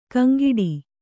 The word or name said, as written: ಗೊಂಗಟೆ